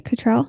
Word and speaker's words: Cattrall: Kim Cattrall, who plays, did I pronounce her last name right? Cattrall? – Yeah, you crushed it.